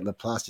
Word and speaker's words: LaPlace: That's how you say it, Laplace.